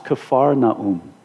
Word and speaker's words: Kfar Naum: It's pronounced Kfar Naum.